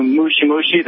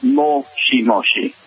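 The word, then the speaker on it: moshi moshi (もしもし): Like when you said, you knew mushi mushi, that's actually incorrect pronunciation, for hello over the phone. It's moshi moshi. – Moshi moshi. – Yes, mushi means bug. You essentially said bug bug, but, moshi, and that's really just listening very carefully to their pronunciation.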